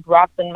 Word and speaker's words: Brockton: Like you know, the Brock Lesnar, which was a wrestler, and Brockton.